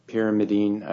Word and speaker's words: pyrimidine: He's saying how the base, can be purine and a pyrimidine.